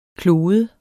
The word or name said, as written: klode